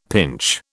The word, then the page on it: pinch: British English